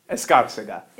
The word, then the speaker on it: Escarcega: Close. Escarcega. Yeah.